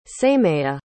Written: sameya

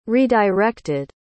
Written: redirected

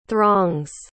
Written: throngs

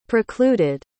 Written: precluded

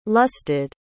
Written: lusted

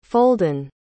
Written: folden

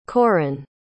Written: Corin

